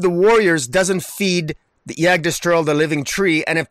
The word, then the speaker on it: Yggdrasil: How do I say that? Yggdrasil? – Yggdrasil, yeah.